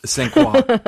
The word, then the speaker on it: St. Croix: Pronunciation is hard, everybody. – La Croix, darling. – St. Croix.